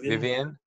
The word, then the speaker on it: Vyvyan: Y-Y-V-Y-A-N. Or no, V-Y-V-Y-A-N. Vivian.